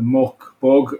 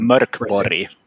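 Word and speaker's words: Mörk Borg: the Mörkborg. And I'm saying that wrong because I'm... – Mörkborg. – There you go.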